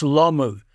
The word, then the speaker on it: Lomu: I said, no, you've got to learn to say his name correctly. It's Lomu, because he's going to be the sensation of these games.